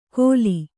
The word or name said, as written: ಖೋಲಿ